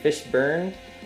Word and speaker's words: Fishburne: Fishburne.